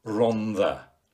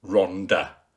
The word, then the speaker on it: Rhondda: By the way, I'm a stickler for Rhondda, not Rhondda, as some newsreaders from further afield have been known to pronounce it. The double D in Welsh is definitely not a D sound.